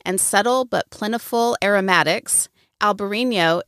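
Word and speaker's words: Albariño: Alvarinho is the Portuguese spelling and pronunciation with a V like Victor, and Albarino with a B like boy is the Spanish spelling and pronunciation.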